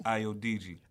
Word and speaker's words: Ayodeji: Ayodeji.